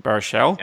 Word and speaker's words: Baruchel: So Jay Baruchel, I don't know Baruchel, if I pronounced that correctly. Baruchel? – Yeah. I think that's right.